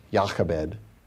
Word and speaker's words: Jochebed: Now, at 3 months old, the woman who is named Jochebed, or I think it's pronounced Jochebed, is how the Hebrew language would pronounce it.